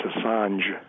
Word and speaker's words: Assange: It's Assange.